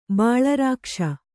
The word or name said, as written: ಬಲರಕ್ಕಸಿ